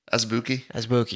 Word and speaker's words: Azubuike: Udoka Azubuike? – Azubuike.